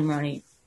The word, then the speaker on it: Maroney: And I have a group, Kelly Maroney Official Fan Group.